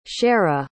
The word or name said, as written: shara